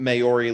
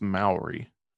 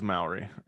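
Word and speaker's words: Māori: But, you know, listener, if you would like to look into some Maori legend. – I think I might be wrong here. I think that's pronounced Maori. Yes, it is Maori. Just looked it up, confirmed.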